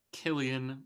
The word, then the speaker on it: Cillian: Cillian Murphy. – Let's go. He got it right. – Because I remembered. – I remember the time when you were saying Cillian, and you know.